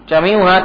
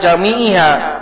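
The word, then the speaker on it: جميعها: Jami'uha taukid ke awsaf, bukan ke kamal. Makanya bacanya jami'uha, bukan jami'iha.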